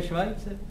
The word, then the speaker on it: Schweitzer: No to se vyslovuje Švajcer.